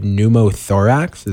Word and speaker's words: pneumothorax: But we were sitting there, and I couldn't pronounce this word, and I still probably will botch it. But CJ McCollum on the Pelicans had pneumothorax. Is that correct? – I think you said it right.